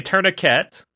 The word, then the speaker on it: tourniquet: Yeah, we don't pronounce the T. – So, we say tourniquet, but.